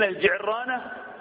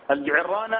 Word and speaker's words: الجعرانة: فلما كانت الدائرة على مالك بن نويرة ومن كان معهم جلس النبي في الجعرانة يوزع الغنائم غنائم هوازن، ثم أهل من الجعرانة ودخل إلى مكة. النقاش الجعرانة أو الجعرانة تنطق هكذا وهكذا، هل هي من الميقات أم خارج الميقات أم داخل الميقات؟